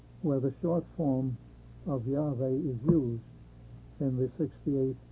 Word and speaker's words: Yahweh: And of course, this indicates that Yahweh would be the correct way to pronounce it.